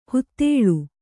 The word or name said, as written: ಹೂದಳ